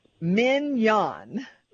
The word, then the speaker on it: Mignon: look her name is pronounced like the steak mignon mignon and and that and,